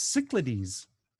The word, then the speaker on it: Cyclades: It's Cyclades.